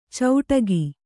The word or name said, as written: ಚೌಟಗಿ